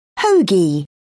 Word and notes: hoagie: British English

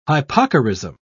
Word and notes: hypocorism: British English

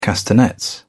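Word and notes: castaway: British English

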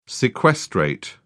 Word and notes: sequestrate: British English

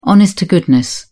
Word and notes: honest to goodness: British English

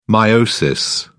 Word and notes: meiosis: British English

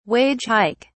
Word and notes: wage hike: British English

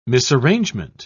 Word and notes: misarrangement: British English

